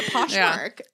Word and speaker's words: Poshmark: And I'm like, well, that's not the name. – Because you won't say it correctly. Because you're not. Poshmart. – Yeah, Poshmark.